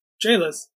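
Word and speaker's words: gaolers: With, and here's a word that I said I was going to learn how to pronounce and didn't, G-A-O-L-E-R-S. – Jailers. – Oh, is it literally just jailers? – Yeah, the old word for jail was G-A-O-L.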